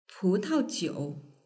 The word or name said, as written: putaojiu